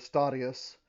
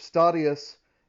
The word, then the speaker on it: Statius: If Statius is to Virgil, then Dante is to whom? – Yeah. Yeah, so you're right to pronounce it with the Latin pronunciation. I'm going to go ahead and say Statius.